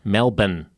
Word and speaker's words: Melbourne: But you're still pronouncing the R in Melbourne. – Melbourne. – Melbourne. – It's like a bun.